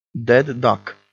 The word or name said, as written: dead duck